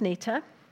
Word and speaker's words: Nita: So, my name is Nita, and you spell that N I T A.